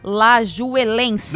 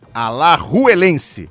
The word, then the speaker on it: Alajuelense: Alajuelense. – Não. É Alajuelense. Ah, obrigada, Everaldo Marques.